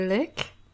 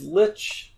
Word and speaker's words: Lich: You pronounce the wizard number 4, you say Lick? – It's Lich. It's not a word I use often enough to, like, have a natural pronunciation. It should be Lich, right? – I have no idea.